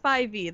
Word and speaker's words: SPYV: It is SPYV. That is the correct way to say that.